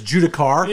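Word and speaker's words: Judiciar: I'm pretty sure it's Judicar. – You know, whatever. – I think it's a hard C, not a soft C.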